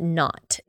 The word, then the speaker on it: Nantes: I think you pronounce it Nantes. It's N-A-N-T-E-S.